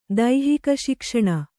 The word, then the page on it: ದೈಹಿಕಶಿಕ್ಷಣ: daihika śikshaṇa